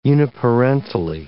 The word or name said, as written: uniparentally